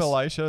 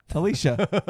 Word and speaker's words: Elisha: Is her name Elisha as well? – No, Alicia. – it's spelled Elisha, isn't it? – I don't remember.